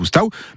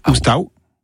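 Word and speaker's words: oustau: Donc, la fin du mot oustao s'écrit à U en provençal, et ça ne se prononce pas O, mais au.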